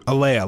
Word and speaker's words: Alia: Alia, like Leia Star Wars.